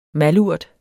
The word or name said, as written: malurt